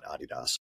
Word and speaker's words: Adidas: So they do all these things like, you know, hey, eh, here, here, um, so um, Adidas, or if you want to be pretentious and correct, call it Adidas because Adi Is that really how you pronounce it? Yeah, because the company was uh, was Adi Dassler.